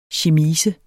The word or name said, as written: chemise